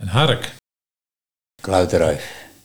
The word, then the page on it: Kluitenruif: Nieuw lekkerlands